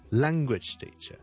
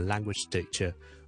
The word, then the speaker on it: language teacher: 好了，即使我这两个字不是黐在一起，中间有个 space 在这里，例如我们那个 language teacher， OK，语言老师 I am a language teacher， language teacher，你当它是一个四个 syllable 的一个字，而个重音放在前面， I'm a language teacher， I'm a language teacher， I work for 哪一间学校咁样？OK， I'm a language teacher， language teacher。